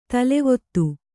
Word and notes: ತಲೆವೀದಿ: talevīdi

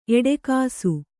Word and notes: ಎಡಗೂಸು: Kannada

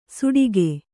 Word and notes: ಸೂಡಿಗೆ: sūḍige

